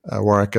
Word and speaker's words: Warwick: I'm active on LinkedIn, Warwick Fairfax, Warwick as a silent W in the middle there.